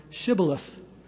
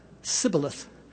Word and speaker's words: Shibboleth: If they answered no, the Gileadites would ask them to say the word Shibboleth, a Hebrew word meaning stream. Ephraimites would pronounce the word with an S sound, Sibboleth, because they had difficulty pronouncing the SH sound.